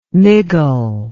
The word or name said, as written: miggle